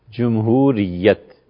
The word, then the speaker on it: جمہوریت: یہ اصل میں لفظ ہے جمہوریت، جمہوریت جیم پر پیش ہے، جمہور کی رائے یہ ہے، جمہور علماء اس بات پر متفق ہیں، لیکن یہ تلفظ ذرا مشکل ہے، جمہور تلفظ مشکل ہے نا، اس لیے لوگوں نے جیم پر زبر.